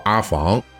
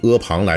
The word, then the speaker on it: 阿房: 阿房宫赋这两个字的读音啊，其实是有争议的。也有人认为呢，应该读作阿房，但是呢，在这儿呢就以阿房来读啊，因为这个是咱们呃大多数人可能认可的这个读音。